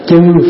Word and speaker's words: chư: Cái hôm trước mình học rồi đó, thay vì chữ trừ đó, đúng ra phải đọc là chư phiền não mới đúng, chứ không có trừ.